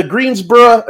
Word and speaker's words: Greensboro: For inexplicably, the Greensboro, and I'm saying Greensboro because that's how they pronounce it over there. I learned from the broadcast. It's not borough.